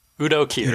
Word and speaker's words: Kier: How you pronounce that thing? Kier or Kier? – Yeah. Udo Kier. – Udo Kier. Kier, okay. Just making sure I pronounce it right.